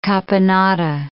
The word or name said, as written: caponata